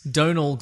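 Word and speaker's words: Domhnall: Donal Gleeson. Apparently it's pronounced like a donut, but with an L.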